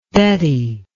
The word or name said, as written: Betty